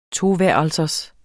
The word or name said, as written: toværelsers